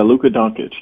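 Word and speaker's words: Luka Dončić: But can you tell me how to pronounce his name so that I have it correct? Is it Doncic? – Yeah, Luka Doncic.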